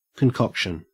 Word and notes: concoction: Australian English

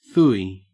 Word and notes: phooey: Australian English

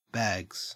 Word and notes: bags: Australian English

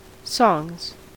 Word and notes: songs: American English

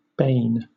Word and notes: bane: British English

